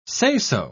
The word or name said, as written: say-so